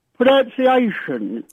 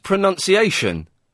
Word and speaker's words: pronunciation: You've mispronounced pronunciation. – Oh. – It's pronunciation, not pronunciation. Pronounce. There's no O. The second O isn't there.